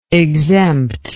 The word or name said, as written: exemptible